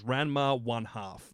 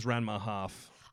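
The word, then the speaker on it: Ranma ½: The actual official pronunciation is Ranma One Half. That's what they market it as. I always thought it was Ranma Half.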